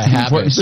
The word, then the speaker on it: Habish: Yeah, Habish. Habish is how I say my last name. In Arabic, it's just HBSH, and then we have the short vowels, which are the A's.